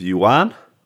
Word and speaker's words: yuan: So people were saying, I believe, last week that they didn't like that Binance was allowing people to buy crypto with yuan, I believe. – With what? – Yuan. I know how to pronounce it now, P-Money.